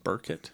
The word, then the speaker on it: Byrkit: And one of the things I understand that James Ward, is it Burkett or? – Burkett. – Yeah. Let's pronounce it Burkett. It's spelled B-Y-R-K-I-T.